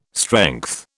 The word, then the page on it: strength: British English